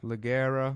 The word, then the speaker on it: Leggera: Isn't it Leggera? – Oh, I don't know. Here's the Italian one here. How do you say it? – It's Leggera. – Leggera. – It wouldn't be Leggera. It's G's, not J's, I guess. Anyway. – It depends. If you're pronouncing it in English, it'd be Leggera.